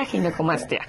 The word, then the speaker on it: ginecomastia: Ginecomastía, ginecomastia.